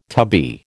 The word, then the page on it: tubby: American English